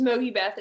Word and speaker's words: Mogi Beth: So, um, hey y'all, my name is Mogi Beth.